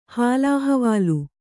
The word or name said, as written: ಹಾಲಹವಾಲೆ